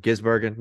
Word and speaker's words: Gisbergen: Way better than what Shane Van Visbergen could be. Biz. – Damn it, did I say his? Did I say his name wrong? Gisbergen. No, no, we're good. – Gisbergen. Shane Van.